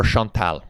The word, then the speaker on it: Chantal Perron: 6 Degrees has teamed up with the fantastic actress Chantal Perron. – Yes. – Or Chantal, which is how you actually pronounce right.